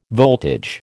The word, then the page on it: voltage: American English